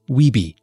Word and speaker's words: WBE: There is also the Women Business Enterprise, or WBE, designation, pronounced WEBE.